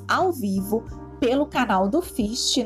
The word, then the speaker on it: FHIST: Lembrando que FIST se escreve F-H-I-S-T mudo.